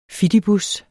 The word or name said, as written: fidibus